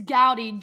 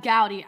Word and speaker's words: Goudy: It's Gowdy. G-O-U-D-Y, Gab Gowdy.